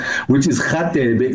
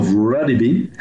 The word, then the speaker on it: Radebe: No, to be honest, I mean, I've tried, you know, a few times just to pronounce it properly, which is Khatebe instead of Radebe.